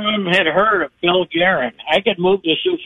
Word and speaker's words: Guerin: It's Guerin. G-U-E-R-I-N.